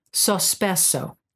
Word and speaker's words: sospeso: The Cafe Sopresso is actually something that, Sospesso. S-O-S-P-E-S-O.